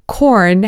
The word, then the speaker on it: Quorn: First of all, Korn has a vegan veggie burger. Have you heard of Korn? It's spelled Q-U-O-R-N.